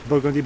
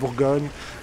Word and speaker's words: Bourgogne: So we say Toulouse Street and Conti and not Burgundy, but Bourgogne.